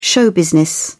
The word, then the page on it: show-business: British English